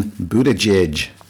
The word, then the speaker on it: Buttigieg: Like Gay Pettigrew. Although, wasn't he a character in Harry Potter? – Scotty. First of all, you say his name Buttigieg. You should know that by now. Pettigrew, or however you said it.